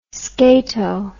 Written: scato

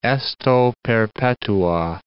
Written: esto perpetua

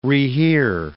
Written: rehear

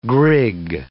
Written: grig

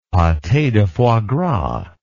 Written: pate de foie gras

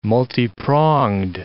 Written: multipronged

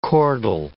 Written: chordal

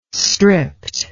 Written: stript

